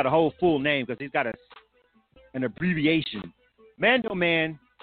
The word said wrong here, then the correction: Mandel: all right. Mandel. My bad. Mandel. I'm sorry, I was saying it wrong. Mandel. The artist's name, Mandel. The song is I-T-I-L-Y, which stands for I Think I Love You.